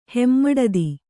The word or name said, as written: ಹೆಮ್ಮಡದಿ